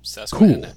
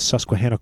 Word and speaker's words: Susquehanna: What is that? Susquehanna? How do you say that? – Susquehanna. – Cool. Shit, yeah. So the Lehigh Valley Coal Company and the Susquehanna